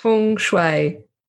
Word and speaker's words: Feng Shui: Feng Shui. – Yay. – Yes. – Yes. Yeah. – Can you explain to us how you are connected to Feng Shui? – I came to learn about Feng Shui about 3 years ago,